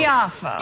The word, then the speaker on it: Tiafoe: Tiafoe.